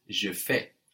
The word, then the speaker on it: je fais: I do in French is je fais. I do, je fais. – Je fais. – That's spelled F-A-I-S. Je fais. And of course, we have the AI giving us an E sound, like in maison.